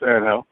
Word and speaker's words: Angel: Yeah, you say it the Spanish way, it's Angel, so you're good.